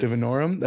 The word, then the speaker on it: divinorum: It's like salvia divinorum or divinorum. That sounds right.